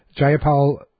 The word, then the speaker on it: Jayapal: Jayapal tweeted, am I pronouncing that right? – Your yes.